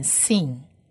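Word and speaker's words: sin (שׂין): 再来往下一个字，像中文的山这个字，我们可以发现它的点是点在左上方，左上方的话表示这一个字念 sin，右上方的话是 shin，左上方是 sin。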